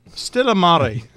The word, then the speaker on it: Stillamare: Am I pronouncing that correct? – Stillamare. – That's much better.